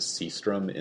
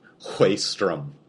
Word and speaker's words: Sjöström: So, speaking of which, we're going to use the Hollywood pronunciation of his name when in the 1920s, when he moved over to the U.S. and started making movies. He was credited as Sjöström instead of his actual Swedish name, which we looked up on the internet and appears to be pronounced Sjöström or something like that.